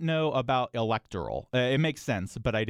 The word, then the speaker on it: electoral: Okay, first I'm going to say pronunciation-wise, it technically, usually, the original things was it's electoral, not electoral, and mayoral, not mayoral. That's more recent, the stress on the or part.